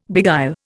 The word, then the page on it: beguile: Indian